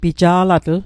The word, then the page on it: pija'latl: Mi'gmaq